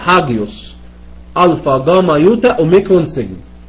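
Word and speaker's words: هاجيوس (ἅγιος): لما أجي أقول لك على صفة زي كلمة هاجيوس يعني قدوس أو قديس، أنا بنطقها هاجيوس لأن في تنفس هائي فوق الألفا وحرف الجاما وحرف اليوتا أوميكرون سيجما. هتلاقي واحد تاني ينطقها ايوس باليوناني الحديث، صح يا دكتور؟ صح، لان اليوناني الحديث ما فيهوش تنفس هائي، فمش هيقول ها، هيقول ا، والجاما يوتا في اليوناني الحديث تتنطق اي، تبقى ايوس ايوس عادي.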